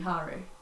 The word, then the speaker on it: Haru: Haru, that's the stupid way to pronounce his name. – It pisses me off. It's definitely Haru.